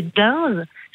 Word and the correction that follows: Deinze: Ça s'écrit D-E-I-N-Z-E. Denz, pardon. – C'est en Flandre, ça, ou c'est peut-être une commune à facilité. Denz, oui, c'est ça.